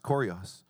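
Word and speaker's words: Kurios (Κύριος): In the original language, it's pronounced Kurios.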